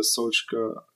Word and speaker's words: Solskjaer: Der hatte unter Solskjaer ganz schlechte Karten.